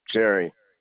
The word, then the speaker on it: Jerry: Jerry. J-E-R-R-Y. – And your last name? – Larson. L-A-R-S-O-N. – L-A-R. – S-O-N.